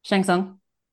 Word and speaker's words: Shang Tsung: Shang Tsung.